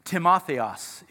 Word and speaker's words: Timotheos: Timotheos is how you pronounce his name in the Greek.